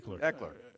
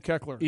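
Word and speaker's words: Ekeler: Eckler. It's I like call him Keckler.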